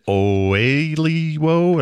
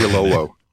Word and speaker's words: Oyelowo: David Oyelowo, and how to say his last name. It's very accurate. Oyelowo.